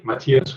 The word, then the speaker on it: Matthias: Matthias schreibt man mit TTH und dann minus Hammer.